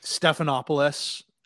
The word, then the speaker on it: Stephanopoulos: George Strompopoulos the guy on ABC. I can't even pronounce his last name. Yeah. – He faded off, but then came back. – Stephanopoulos. – Right? – Stephanopoulos.